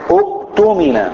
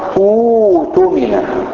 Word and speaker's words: اؤتمن: لكن في كلمة زي اؤتمن في سورة ايه ديت؟ البقر، تنطق اؤتمن، اؤتمن، لكن انا نطقت ايه؟